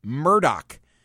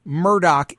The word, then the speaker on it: Murdaugh: To look at the headline, I think you'd pronounce it Alex Murdaugh, but I know from her prior appearance, the proper way to refer to this guy is Alex Murdaugh. This is Valerie Bauerlein. Hi, Valerie. Thank you for coming back. – Good morning. Thanks for having me. – Am I right? Alex Murdaugh is the way that I should pronounce his name.